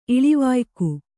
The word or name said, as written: ಇಳಿವಾಯ್ಕು